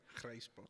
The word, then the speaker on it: grysbok: Uh, fantastic shot, fantastic guidance, and uh, it's a animal I didn't know about, but the grass buck. Um, and I can't say it like they do. They have a really cool way of saying it. grysbok. Yeah, there you go.